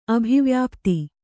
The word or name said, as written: ಅಭಿವ್ಯಾಪ್ತಿ